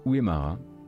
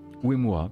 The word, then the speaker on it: Uemura: Donc on apprend effectivement, je vois la news qui est tombée il y a une trentaine de minutes, le, décès de Masayuki Uemura. Uemura, pardon, super, très bien.